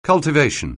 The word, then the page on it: cultivation: anglais